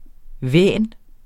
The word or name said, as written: væn